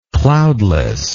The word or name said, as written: cloudlessness